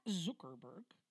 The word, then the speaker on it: Zuckerberg: Mark Zuckerberg, oh, I think I actually pronounced that correctly this time.